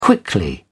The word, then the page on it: quickly: British English